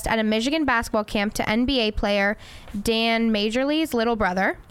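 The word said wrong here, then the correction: Majerle: Marley. – You have a J in it. Yeah, but that's how it's pronounced. The J is silent.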